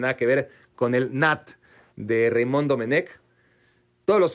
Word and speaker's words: Nantes: Es que Nantes se pronuncia Nat, pero si dices Nat, pues no te va a entender nadie. O sea,